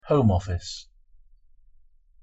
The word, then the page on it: home office: English